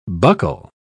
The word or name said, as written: buckle